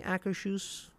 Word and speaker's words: Akershus: I think I'm saying Akershus correctly. – I think so. – I think that's how you say it.